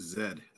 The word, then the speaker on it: Z: I think you pronounce it Zed, actually. – Is it Task Force Zed? – Well, I say Zed because I say my Z's like British Z's, so You are so whatever.